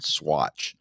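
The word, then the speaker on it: Saguache: It's spelt like Saguachahee, but it's actually called Swatch is the name of this little town.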